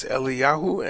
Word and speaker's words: Eliyahu: Which, of course, aren't pronounced that way in Hebrew. I think it's Eliyahu and Elisha, but unimportant.